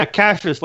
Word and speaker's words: Cassius: Yeah, Cassius, like Cassius Clay.